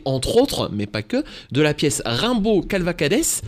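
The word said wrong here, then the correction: Cavalcades: Je prononce bien, c'est ça euh, Cavalcade. Cavalcade, ben bah voilà, tout simplement.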